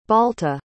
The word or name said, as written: balta